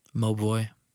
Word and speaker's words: Mobvoi: It's Mobvoi. – Mobvoi.